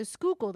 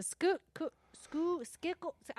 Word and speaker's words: Schuylkill: I'm like, the Schuylkill. They're like, the Schuylkill. I'm like, oh, no, just don't.